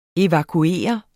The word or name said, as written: evakuere